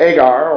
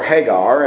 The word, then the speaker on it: Hagar: The one from the Mount Sinai, which gendereth to bondage, which is Agar or Hagar, as it's pronounced in the Genesis.